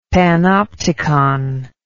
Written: panopticon

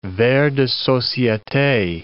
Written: vers de societe

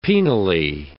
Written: penally

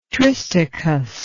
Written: tristichous

